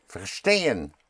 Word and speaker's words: verstehen: Ver is spelled V-E-R, and the V always has the sound of an F. Verstehen. Wir verstehen.